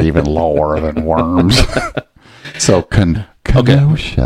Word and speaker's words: Kenosha: It's Kenosha.